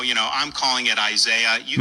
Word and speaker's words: Isaiah: Porque la I en inglés se le dice I, y entonces pues Asaya, aunque nosotros en español es más fácil Isaías.